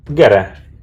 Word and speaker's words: Gere: You know and uh, Gere Arpad is uh, it, so this is how it's pronounced in Hungarian Gere Arpad.